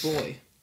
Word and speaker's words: buoy: And they they pronounce buoy as boy.